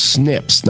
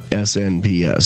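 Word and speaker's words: SNP: It's actually pronounced snips, not SNPS. So snips.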